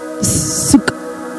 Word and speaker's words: Sukkot: Sukkot, is that how you pronounce it? Close enough, okay, good.